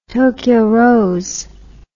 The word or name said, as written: Tokyo Rose